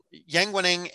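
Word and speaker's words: Yang Weining: Is that it? – That sounds right. – Yeah, Yang Wening and Lei Shisheng. Which is also how, I mean, I looked it up online on how to say it, and that's how they said it as well.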